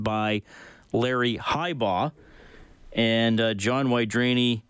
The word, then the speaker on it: Wydareny: So John, common spelling, W-Y-D-A-R-E-N-Y, but people saying it's pronounced Wydrani, born in 1941.